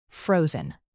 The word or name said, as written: frozen